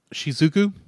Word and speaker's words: Shizuku: Shizuku.